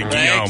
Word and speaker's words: Guillaume: Robert Guillaume. – Yes, pronounce it correctly, Guillaume.